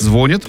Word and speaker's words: звонит: Пахомов напомнил, что похожий сдвиг уже произошел в других глаголах, например, «любит», «ценит» и «курит», где раньше ударение тоже стояло на втором слоге. Точные сроки перемен назвать сложно, но ученый считает вариант «звонит» ударением будущего.